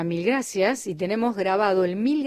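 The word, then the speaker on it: gràcies: Es una que se parece un poco, se escribe como una E, pero en catalán tenemos vocales neutras y esta es una de estas neutras. Está entre la A y la E. Gracias. Mil gracias.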